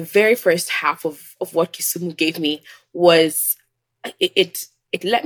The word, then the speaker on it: Kisumu: Kisumu, yes.